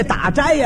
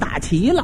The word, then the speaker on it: 打斋: 他也错了一个，这打斋呀，他给念成打齐了，斋字他念成齐了。